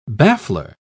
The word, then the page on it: baffler: British English